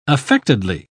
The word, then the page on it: affectedly: English; British English